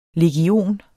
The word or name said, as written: legion